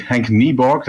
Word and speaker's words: Nieborg: Henk Nieborg, that's how you pronounce it, actually.